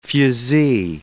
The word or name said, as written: fusee